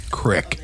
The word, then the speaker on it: creek: It is correctly pronounced crick.